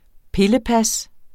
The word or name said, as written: pillepas